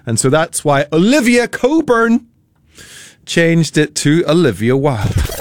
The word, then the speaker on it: Cockburn: It's of Scottish origin, and you pronounce it Coburn. However, it is spelt C-O-C-K-B-U-R-N.